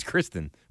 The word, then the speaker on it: Christine: He's like, no, it's Kristen, which is also tough. But, like, it's spelled Christine.